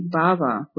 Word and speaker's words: bhava: Both fall into the category bhava with a long A.